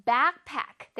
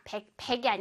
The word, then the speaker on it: backpack: 자, backpack 할 때 앞에 부분 back을 아주 강렬하게 발음해주세요. 뚜렷하게, backpack, backpack. 그냥 백팩이 아니라 입을 위아래로 좀 크게 벌려야 돼. Backpack, backpack. 백팩이 아니라 backpack. Okay, one more time.